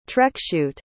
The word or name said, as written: trekschuit